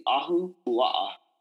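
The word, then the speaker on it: Ahuapua (Ahupuaʻa): The traditional systems of Hawaii, they've been, I'm going to butcher this pronunciation, was it Ahuapua? – It's the Ahuapua system. – Okay, I'm not even going to try it again. – It's two words, and the word is ahu, A-H-U, ahu.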